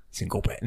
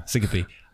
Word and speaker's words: syncope: How do you say syncope? – Syncope. No, syncope.